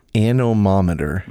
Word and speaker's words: anemometer: Anemometer. – Anemometer. – Ano. – More like ane. Anemometer. – Spell it. – A-N-E-M-O-M-E-T-E-R.